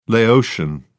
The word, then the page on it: laotian: British English